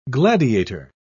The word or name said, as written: gladiator